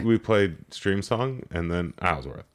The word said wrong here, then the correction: Isleworth: Yeah. – Isleworth. I keep mispronouncing that.